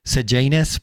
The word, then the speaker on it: Sejanus: Sejanus can also be pronounced Sejanus, but I'm not going to use the latter pronunciation for obvious reasons.